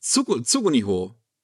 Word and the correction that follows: Tsugunori: Tsugunori, okay. so, Tsugunori, so, so spricht man es aus.